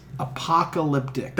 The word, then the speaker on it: apocalyptic: Oh my god, did you say that on purpose just now, or you really think that's how it's pronounced? – Apocalyptic.